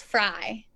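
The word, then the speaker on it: Frey: So it's F R E Y, but it's pronounced Fry.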